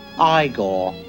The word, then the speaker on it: Igor: It's pronounced Igor.